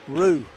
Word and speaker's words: Roux: Chase Rue, R-O-U-X, pronounced Rue, Chase Rue in the game. – Like foe, Rue, yeah, I think.